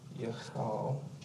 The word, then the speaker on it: Yechal: Yeah, and it's pronounced Yechal. Yechal.